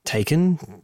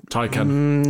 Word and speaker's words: Taycan: Mm-hmm Taken. Taken. Mm, Taken. Yeah, they can't even decide on the pronunciation in the episode,